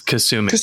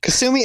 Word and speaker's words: Kasumi: Sahumi, is that how you pronounce it, or is it Tsumi? – I believe it's Kasumi. – Kasumi and Zahid.